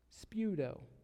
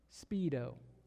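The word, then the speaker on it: speudo (σπεύδω): It's pronounced speudo. And I want you to know that there is no Greek word pronounced speedo.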